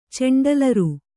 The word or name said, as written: ಚೆಂಡಲರು